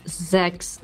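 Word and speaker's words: sechs: So that's sung in German, so that should be pronounced as Zex, Zex, Zex.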